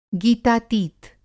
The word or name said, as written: गीतातीत